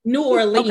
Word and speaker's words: New Orleans: So I find New Orleans men to be New Orleans men. Am I saying that correctly? I'm not sure. – Anyway. New Orleans, okay. Correct. Yeah.